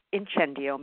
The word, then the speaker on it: incendio: Well, okay, the word incendio means fire. That's the Italian pronunciation of the word incendio. And the word fire, I think that by the time you finish the story, you'll understand where that word comes from.